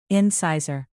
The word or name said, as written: incisor